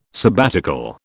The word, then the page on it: sabbatical: British English